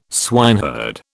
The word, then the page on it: swineherd: British English